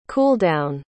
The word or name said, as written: cooldown